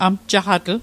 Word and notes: amjaqatl: Mi'gmaq

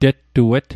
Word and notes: tettuet: Mi'gmaq

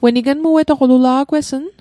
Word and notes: ignmuetoq: Mi'gmaq

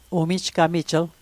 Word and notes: umijgamijl: Mi'gmaq

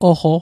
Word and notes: oqo: Mi'gmaq

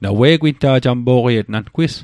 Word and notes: jampo'qiet: jam'po'qiet; Mi'gmaq

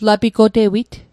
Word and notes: lapigotewit: Mi'gmaq

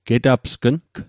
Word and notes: getapsgng: Mi'gmaq

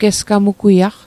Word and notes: gesgamugwiaq: Mi'gmaq

